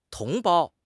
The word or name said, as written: 同胞